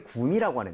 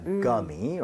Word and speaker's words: gummy: 그런 것을 이렇게 표기하는데 대신에 우리는 구미라고 하는데 영어에서는 gummy라고 하게 됩니다. gummy, right? So 구가 아니라 g에 가깝게 발음을 하셔야 됩니다.